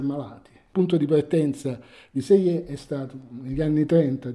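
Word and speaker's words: Selye: Hans Seyer, si pronuncia, anche se tutti gli inglesi e gli americani pronunciano Seeley, che è, diciamo, l'ideatore della teoria dello stress.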